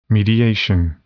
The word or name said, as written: mediation